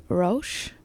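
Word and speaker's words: Róis: And that is by the artist called Roche, but it's spelled R-O-I-S with an accent on it if you are looking for it.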